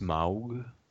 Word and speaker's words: Smaug: Think of Smog. from the Lord of the Rings. – It's pronounced Smaug.